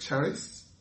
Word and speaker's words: charis: Grace, the word that translates grace in the New Testament, It comes from the word charis, C-H-A-R-I-S, or charis, if you pronounce it that way, which means favor, blessing, kindness.